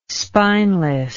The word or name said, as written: spineless